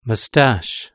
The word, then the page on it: moustache: British English